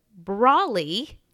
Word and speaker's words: Brawley: So we're back with Shannon Brawley. – Yeah. – She taught me how to say her name during the break. Brawl, like brawling.